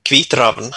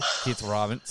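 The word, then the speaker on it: Kvitravn: Now, forgive my pronunciation. It's Vitraven. How do you pronounce it? – Kvitraven. – Kvitraven. Sorry. I apologize.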